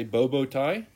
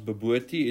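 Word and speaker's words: bobotie: So usually the overseas guys say bobo thai, but it's actually pronounced babuti. It's a traditional South African meal made with impala, mince with yellow rice and egg on top.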